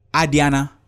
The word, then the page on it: adiana: Efik